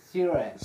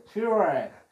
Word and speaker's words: Sure: Pues, Pero corríjanos, no, no se dice Sara, aunque sea española, la marca se dice Sure. – Sure.